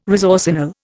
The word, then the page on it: resorcinol: Indian